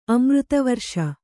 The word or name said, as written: ಅಮೃತವರ್ಷ